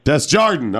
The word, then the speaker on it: Desjardin: Desjardin, okay.